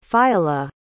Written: phiala

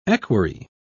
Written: equerry

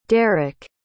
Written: deryk

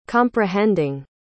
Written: comprehending

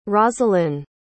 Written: rosalynne

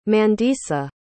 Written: mandisa

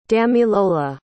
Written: damilola

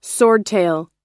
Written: swordtail

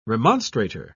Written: remonstrator

